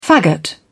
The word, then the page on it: faggot: British English